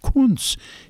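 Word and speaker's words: Kuntz: K-U-N-T-Z. I says, I'm German extraction. My father spoke German. I says, in Germany, that's pronounced Kunz. She said, that's silly. It's that. No, it's Kunz.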